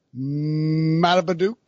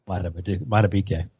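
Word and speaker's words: Madubuike: They had Justin Matabeduke as well. Did I say it wrong? – Matabeduke.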